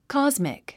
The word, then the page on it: cosmic: kάzmik; コォズミック